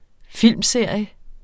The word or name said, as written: filmserie